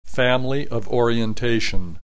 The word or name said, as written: family of orientation